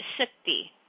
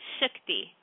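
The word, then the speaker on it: shakti (शक्ति): So strength in Hindi is shakti. Okay, it's shakti. And that is spelled S as in Sam, A-K-T-I. So S as in Sam, A-K-T-I. And it's shakti.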